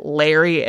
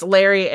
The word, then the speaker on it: Lauri: Some people say that I just want to point out, if you're like reading the grandfather's name, it's spelled Lauri, but I saw that someone says they pronounce it Larry and not Lori. – Okay. – So it's Larry and Britta Wuornos.